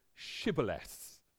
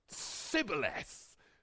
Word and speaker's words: shibboleths: If the man said, no, I'm not, they would tell him to say, shibboleths. If he was from Ephraim, he would say sibboleths, because people from Ephraim cannot pronounce the word correctly.